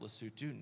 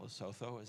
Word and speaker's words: Lesotho: It is its own independent country, pronounced Lesotho, not Lesotho, as it looks like it. You'll hear that pronunciation a lot, but that's not right.